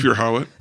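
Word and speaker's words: Thufir Hawat: Fufir Howit.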